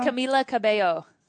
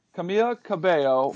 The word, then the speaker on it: Camila Cabello: Were you guys more excited about Camila Cabello? – Havana. Right. – Did I say it right? – Camila Cabello. – Camila Cabello, her new album, right.